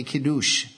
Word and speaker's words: Kiddush: It's also called in the Hebrew the Kiddush, or the Kiddush, depending on how you want to pronounce it.